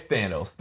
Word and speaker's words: Thanos: They say Thanos. – Okay, we're saying it differently. I just wanted to check. I didn't know. – I don't care. But it's Thanos. They say Thanos in the movie, but I like saying Thanos.